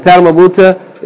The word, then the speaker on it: ta marbuta: Okay, so ha is pronounced, yani ha fatha ha, and ta marbuta is pronounced ta marbuta fatta ta. Unless you pause on it, then it's pronounced the same as the ha.